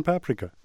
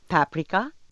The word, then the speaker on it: paprika: So grow your own next year and make your own paprika. – Paprika? – Yeah. Paprika, not paprika. I used to know this Hungarian guy, and he said that all Hungarian words are stressed on the first syllable.